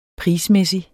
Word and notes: prismæssig: -ˌmεˀsi; -ˌmεsi

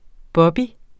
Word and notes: bobby: ˈbʌbi

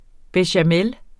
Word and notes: bechamel: beɕaˈmεl; bεɕaˈmεl

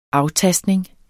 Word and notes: aftastning: ˈɑwˌtasdneŋ

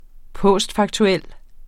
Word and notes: postfaktuel: ˈpɔˀsdfɑgtuˌεlˀ; ˈpoˀsd-

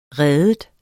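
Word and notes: ride: verbum

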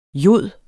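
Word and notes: jod: Danish

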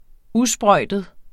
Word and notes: usprøjtet: ˈuˌsbʁʌjˀdəð; ˈuˌsbʁʌjdəð

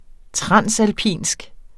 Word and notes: transalpinsk: ˈtʁɑnˀsalˌpiˀnsg; tʁɑnsalˈpiˀnsg

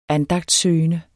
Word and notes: andagtssøgende: [ -ˌsøːjənə ]; [ -ˌsøˀjənə ]

